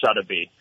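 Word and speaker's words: Suddaby: and it's pronounced Sotheby.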